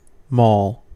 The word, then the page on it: maul: American English